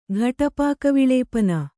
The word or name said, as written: ಘಟಪಾಕವಿಳೇಪನ